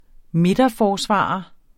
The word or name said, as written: midterforsvarer